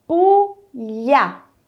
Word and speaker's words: Puglia: Eu falei com o som de LH, não falei? Então, em italiano, quando o G estiver junto do L, a pronúncia será de LH. Por isso eu falei Puglia e não Puglia.